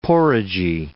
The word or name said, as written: porridgy